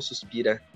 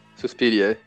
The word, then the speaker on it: Suspiria: Mas Suspiria ou Suspira? Suspiria, né? – Suspiria.